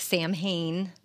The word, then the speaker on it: Samhain: So yes, so the the, the real Halloween is pronounced Samhain. It looks like Sam Hain on your calendars, but Samhain is the Celtic holiday.